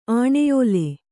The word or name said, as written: ಆಣೆಯೋಲೆ